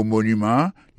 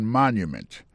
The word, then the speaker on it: monument: Pou mo monument, il tombe sou premier syllabe la. The Washington Monument. Monument, se kon se wè di monument an anglais.